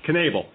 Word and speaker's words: Knebel: I believe it's pronounced Knebel, Corey Knebel? – Knebel. – Okay, Corey Knebel, which is odd, but regardless of that, he's 22 years old and a right-hander, and he was really raced through the system.